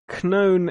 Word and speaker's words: known: The comic collector known as Chris, it was all with K's, sorry. – You always said known isn't pronounced, you don't pronounce the K.